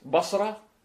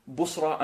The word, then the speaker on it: Basra: Or like how Basra as a city, okay, is pronounced in three ways. Basra, Busra and Bisra. You can say all three.